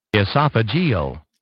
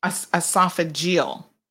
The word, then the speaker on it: esophageal: I want to say it's esophageal, but let me just double check. – Esophageal. – Esophageal, which is part of esophagus.